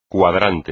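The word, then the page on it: cuadrante: español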